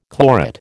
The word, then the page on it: chlorate: American English